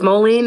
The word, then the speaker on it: Moline: Is that how you pronounce it? Moline? Moline? – It's Moline, so it's, Illinois.